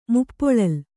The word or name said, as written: ಮುಪ್ಪೊಳಲ್